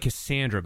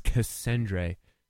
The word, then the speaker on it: Cassandre: It looks like Cassandra, but it's not. It's Cassandre.